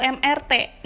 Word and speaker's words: MRT: Jadi sebenarnya tuh dibacanya MRT atau MRT. Nah, kalau katanya Ivan Lanin, kan, meski MRT berasal dari bahasa Inggris, ia dilafalkan sesuai dengan nama huruf dalam bahasa masing-masing saat digunakan.